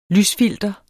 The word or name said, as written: lysfilter